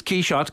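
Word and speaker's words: Quichotte: But the reason why it's Quixote in this case is that our character hears the Massenet opera, which was by the French composer. Mm. So Quixote is the French pronunciation of Quixote.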